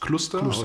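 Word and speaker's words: Kluster: Do you pronounce it German? Cluster? – Cluster, with K. Then. – Yeah.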